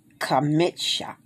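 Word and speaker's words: Kamitsha (חמישה): The feminine tense is A-R-B-A. A-R-B-A. Abba. 5. 5 is Kamitsha. Kamitsha. C-H-A-M-I-S-H-A. As you can see, I said K because the K is pronounced as a K. The C-H is pronounced as a K. Okay, the feminine tense is Kamish. C-H-A-M-E-S-H.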